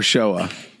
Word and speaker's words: Shoah: And did I pronounce that correctly, Zachor, Shoah? – You did great. You did fantastic.